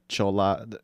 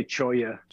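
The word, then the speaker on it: Cholla: Also the Chola, is it Choya or Chola? How would you properly pronounce that? – I say Choya. – Choya, yeah. That's probably the Spanish, the correct way to say it, right?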